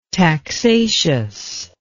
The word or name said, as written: taxaceous